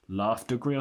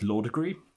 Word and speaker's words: Laughdugry: The name is spelt L-A-U-G-H-D-U-G-R-Y, which of course looks like it should be Laugh Degree or something, but it's not. It's Law Degree. It's Oliver Law Degree.